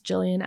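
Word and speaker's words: Jillyan: So my name on Facebook is just Jillian Adams.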